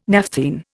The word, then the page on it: naphthene: Indian